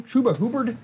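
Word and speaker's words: Chuba Hubbard: So good old Tuba Hubbard over there. – Tuba Hubbard. – How do you say his name? Tuba Hubbard. Tuba Hubbard. Okay.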